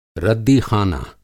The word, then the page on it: रद्दीख़ाना: Hindi